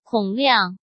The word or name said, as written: Kong Liang